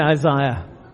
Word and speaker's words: Isaiah: Is it Isaiah or Isaiah? We say Isaiah.